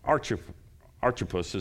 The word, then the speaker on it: Archippus: and to Archippus, is how you say his name, our fellow soldier, and to the church in your house.